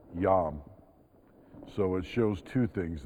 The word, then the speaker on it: Yam: And he does this because the Hebrew word for sea, excuse my Hebrew pronunciation, is Yam, Y-A-M.